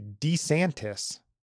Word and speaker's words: DeSantis: Kind of grabbed a lot of the headlines, and that was Florida Governor Ron, it's actually DeSantis. I think if you listen to his ad, he says Ron DeSantis, not DeSantis. We've been saying it wrong all this time.